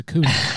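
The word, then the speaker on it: Acuña: And then our good friend of the pod, Ronald Acuna. Let me throw you some stats out there, because Ronald Acuna, I'm sorry, I keep pronouncing his name wrong. It's Acuna.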